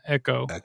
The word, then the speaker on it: Ekoa: Well, just so you guys know, the band that I'm talking about, or the artist specifically that I'm talking about, Echo.